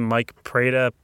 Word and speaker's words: Mike Prada: My name, Mike Prada, spelled like the Italian company.